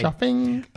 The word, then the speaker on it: chafing: It's chafing, but it's spelled with a C-H-A-F-I-N-G.